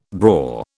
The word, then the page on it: braw: British English